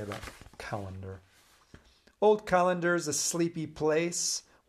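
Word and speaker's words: Callander: This one's called Calendar, not spelled like the one, the Roman calendar that we use to mark the days. It's C-A-L-L-A-N-D-E-R, a place in Scotland.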